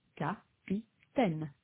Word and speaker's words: capitaine: So when French speakers say the word capitaine, they stress all three syllables equally, capitaine.